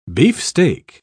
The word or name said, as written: beefsteak